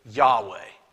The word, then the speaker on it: Yahweh: Now, the consensus among scholars today is that the original pronunciation was probably Yahweh.